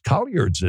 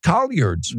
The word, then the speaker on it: collards: I said, how do you pronounce C-O-L-L-A-R-D-S? And she said, that's Colliards, isn't it? I'm telling you. – Where's the U? – Just like the dialects in the South, maybe up north, there's a little pocket that I happen to be in where they're pronounced Colliards.